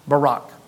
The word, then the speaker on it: Baraq: It was spelled B-A-R-A-Q, but pronounced Barak.